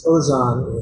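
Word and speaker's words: Tōzan: Dongshan is Tozan in Japanese pronunciation.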